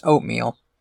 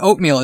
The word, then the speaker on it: Oatmeel: Yeah, but like Oatmeal, it's funny because they spell it O-A-T-M-E-E-L. So looking at it, it looks like a different word. But you say it out loud and it's just Oatmeal.